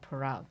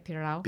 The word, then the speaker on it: pirão: You could bottle that pirão. That's, am I saying it right? Pirão? – Pirão. – Pirão. – Yes.